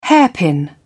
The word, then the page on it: hairpin: British English